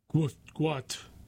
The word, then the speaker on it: Gout: In 2005, Bonna, B-O-N-A, and Monica Gout, pronounced Gout. It's Gout, spelled G-O-U-T, is pronounced G-W-O-T, Gout, sought to leave war-ravaged South Sudan.